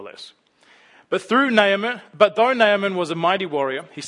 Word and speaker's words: Naaman: For the record, I believe it is pronounced Naaman. I checked Bible dictionaries and things. You know, they've got the pronunciations. You can press play. Naaman.